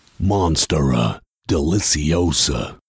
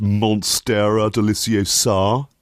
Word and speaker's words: Monstera deliciosa: But to me, the most dramatic and mind-boggling of all is Monstera deliciosa. – Yeah, actually, I think you'll find it's pronounced Monstera deliciosa.